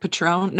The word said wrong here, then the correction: Patreon: No, Patreon. – Patreon.